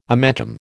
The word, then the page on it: omentum: American English